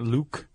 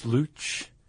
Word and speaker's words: luch: It's not Luke. It's Luch.